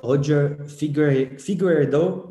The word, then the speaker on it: Roger Figueiredo: So today's guest is Roger Figueiredo.